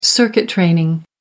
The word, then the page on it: circuit training: American English